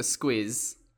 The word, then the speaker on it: squiz: Now, squiz is a bit of a weird word and it's spelt S-Q-U-I-Z. Give us a squiz. – Okay, because I thought you were saying like give us a squeeze, which we might say in the US to be like give me a hug.